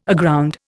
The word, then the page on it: aground: Indian